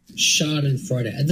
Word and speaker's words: Schadenfreude: Is that really how you pronounce that? Every time somebody says that word on the show, we ask, is that how you pronounce that? – Schadenfreude. That is how you pronounce it.